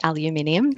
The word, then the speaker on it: aluminium: And we do say aluminium. – Love it. Side note, because we spell it with an extra I. – Okay. – It's not just a pronunciation thing.